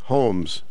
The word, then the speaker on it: Holmes: Clint Holmes. – Holmes. Wow. – Like Sherlock Holmes.